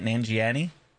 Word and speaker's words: Nanjiani: Nanjiani, something like that.